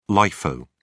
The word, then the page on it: lifo: British English